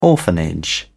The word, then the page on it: orphanage: British English